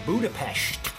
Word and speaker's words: Budapest: London, Lisbon, Rome, Florence, Venice, Munich, Salzburg, Vienna, Budapest.